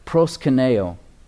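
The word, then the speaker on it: proskyneo: proskuneo. Proskuneo. I hope I'm butchering that not too badly in Greek. Proskuneo. It's spelled P-R-O-S, pros, K-Y-N-E-O. Kaineo is what it looks like, but it's pronounced proskuneo. K-Y-N-E-O on that last part.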